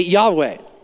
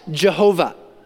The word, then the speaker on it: Yahweh: It's pronounced today Yahweh. Sometimes you will hear it pronounced Jehovah, but it's God's personal name.